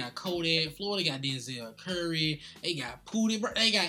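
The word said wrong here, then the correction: Pouya: Oh, my bad. Pooya. I said that wrong.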